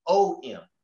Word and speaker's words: Aum: When you utilize the A and the U and you bring those together syntactically and morphology, it makes O-M. But the word is actually A-U-M. It's three. But when you pronounce the word, it's pronounced om, because the A sounded in that is a soft A, but the A is there.